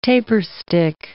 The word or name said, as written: taperstick